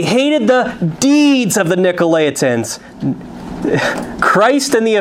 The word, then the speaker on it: Nicolaitans: It says, "But this thou hast, thou hatest the deeds of the Nicolaitans," or the Nicolaitans, it's also been pronounced too, "which I also hate."